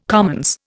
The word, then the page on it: commons: Indian